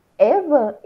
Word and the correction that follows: Ewan: como é que fala o nome dele? – Ewan McGregor.